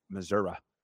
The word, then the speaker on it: Missouri: And that's how they say it, Missouri.